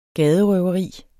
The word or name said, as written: gaderøveri